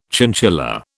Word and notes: chinchilla: British English

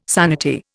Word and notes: sanity: Indian

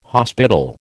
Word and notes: hospital: American English

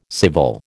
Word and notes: civil: American English

